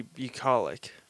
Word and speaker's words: bucolic: The place was surrounded by trees as far as the eye could see, and I still don't know how to say this word. What is it? – The bucolic. – Bucolic.